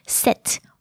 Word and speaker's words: cet: the demonstrative adjective would be C-E-T, pronounced set.